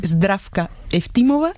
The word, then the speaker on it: Zdravka Eftimova: Zdravka Eftimova y Vera Mutavcheva.